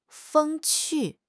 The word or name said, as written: 风趣